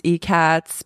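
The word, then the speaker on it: ikat: I think the correct pronunciation is eCat.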